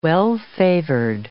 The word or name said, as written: well-favored